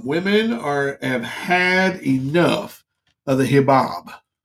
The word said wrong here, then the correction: hijab: No, I'm not saying that right. The hijab. – H-I-J-A-B. – J-A-B.